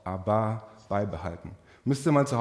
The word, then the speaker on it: Abba: Ähm, Jesus hat uns gesagt, wir sollen zu Gott sagen, Abba. Betonung auf der letzten Silbe, ganz wichtig, nicht die schwedische, schwäbische Popband, das ist auch nicht, die, nicht die schwedische Popband, sondern Abba, unser Vater.